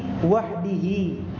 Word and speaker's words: wahdihi: Kemudian nomor 2, al-iqtisaru 'alal ma'i wahdahu afdalu min al-iqtisari 'alal hijarati wahdaha. Afwan, salah tadi saya bacanya, al-iqtisaru 'alal ma'i wahdihi afdalu min al-iqtisari 'alal hijarati wahdaha. Begitu yang benar.